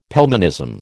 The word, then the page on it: Pelmanism: American English